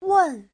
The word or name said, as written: wen4